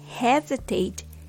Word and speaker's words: hesitate: It has three syllables and has the stress on the first syllable. Hes. Hesitate. The second syllable becomes very weak and the vowel becomes a schwa.